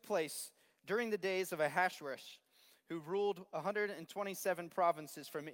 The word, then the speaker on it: Ahasuerus: This is Ahasuerus. That's the way the Hebrews pronounce his name. So the Jews that were living there at the time pronounce his name Ahasuerus, but Xerxes is how the Greeks pronounced it.